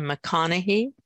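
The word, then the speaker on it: McConaghy: And I think you pronounced her last name McConaughey. Mm-hmm.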